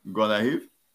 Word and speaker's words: Gonaïves: Well, how do you say that? Okay, everybody knows of Port-au-Prince. Well, how did you say that? – Gonaïves? Oui. – Okay, Gonaïves. Yes. Great.